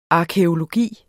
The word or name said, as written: arkæologi